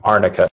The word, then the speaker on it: arnica: Also, I've been using heavily Anarka? How do I say that? I don't know. It's CBD. It's a salve with Anarka. – Arnica. – Thank you.